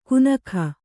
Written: ಗುನಗ

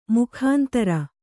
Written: ಮೊಖಾಂತರ

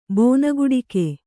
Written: ಬೋನಗುಡಿಕೆ

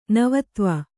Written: ನವತ್ವ

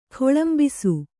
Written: ಖೊಳಂಬಿಸು

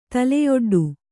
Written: ತಲೆಯೊಡ್ಡು

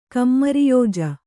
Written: ಕಮ್ಮರಿಯೋಜ

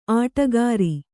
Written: ಅಡಿಗಾರ

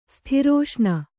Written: ಸ್ಥಿರೋಷ್ಣ

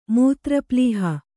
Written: ಮೂತ್ರಪ್ಲೀಹ

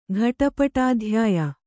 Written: ಘಟಪಟಾಧ್ಯಾಯ

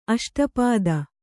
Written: ಅಷ್ಟಾಪದ

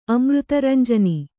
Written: ಅಮೃತರಂಜನಿ

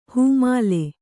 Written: ಹೆಮ್ಮಲೆ

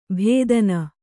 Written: ಭೇದನ

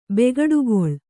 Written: ಬೇಗಡೆಗಳೆ

